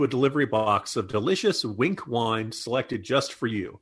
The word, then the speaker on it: Winc: We've talked about Wink before. It's spelled W-I-N-C, and it makes it easy to discover great wine. And that's because Wink's wine experts select wines matched to your taste, personalized just for you, and shipped right to your door.